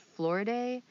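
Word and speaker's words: Floride: I believe her name is pronounced Florida, Florida Calhoun, wife of John C.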